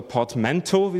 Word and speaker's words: portmanteau: Der erste Satz ist, it's a portmanteau oder portmanteau, wie sie es aussprechen auf Englisch, aus Federation und Universe.